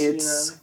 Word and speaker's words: tsunami: It's not really a silent T. – Is no. – It tsunami. – It's tsunami. – Well, it's derived from Japanese. Yeah. So they can put those consonants at the beginning of the word.